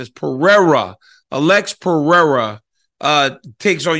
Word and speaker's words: Pereira: I keep saying that wrong. Like, how long will it take me to recognize that it's Pereira and not Pereira? Because a lot of Brazilians call the R, the H, and eh, the whole uh, confusing thing.